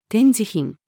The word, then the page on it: 展示品: female